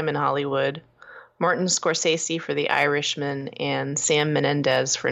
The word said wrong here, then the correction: Mendes: And Sam Sam Mendes, sorry, I added a syllable to his name.